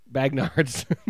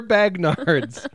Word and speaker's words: Bagnard: B-A-G-N-A-R-D-S. Bagnards. which translates to convicts, air convicts. – Okay. So I don't think you're pronouncing it right. – Air Bagnards? It's definitely Air Bagnards. – It's only Bagnard.